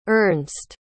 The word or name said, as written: Ernst